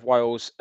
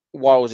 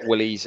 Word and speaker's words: Wiles: So I'm on Twitter just / or at Kev Wiles. Wiles is spelled W-I-L-E-S. You'll be surprised at how many people spell it Willies and like pronounce it wrong and stuff.